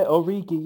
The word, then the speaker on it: Origi: I always pronounce his name wrong, honestly. Origi, please correct me if I'm wrong. – No, that's good. Yeah, Origi, yeah. – Origi, thank you.